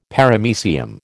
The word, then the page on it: Paramecium: American English